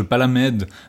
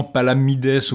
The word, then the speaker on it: Palamède: Un autre ajout énorme de ce roman qui va être assez populaire pour la suite, c'est le personnage de Palamède. Normalement, on devrait dire probablement Palamides ou Palamedes. Le S final se prononcerait probablement, mais on va dire Palamède parce que voilà.